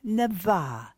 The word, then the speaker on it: naveh: In episodes 1 and 2, I introduced to you a Hebrew word, navah. It's spelled N-A-V-E-H.